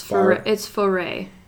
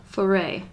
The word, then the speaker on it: Foray: Also, you pronounced it, like, very wrong earlier. It's Foray. – Farah? – Foray.